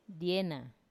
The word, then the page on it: diena: Wamesa